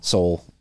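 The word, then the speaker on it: Sowell: Soul.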